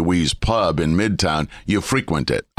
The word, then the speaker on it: frequent: You go to a place frequently, but if you go to a place frequently, you don't frequent it, you frequent it. It's a different pronunciation.